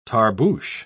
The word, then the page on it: tarboosh: British English